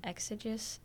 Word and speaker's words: exegesis: exegesis and eisegesis. Did I say that right? – Exegesis? Yeah, exegesis.